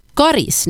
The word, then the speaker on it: Karis: Rekommendationen om långt A, Karis, har funnits sedan början av 1960-talet, och för det så fanns det faktiskt inte uttalsrekommendationer överhuvudtaget.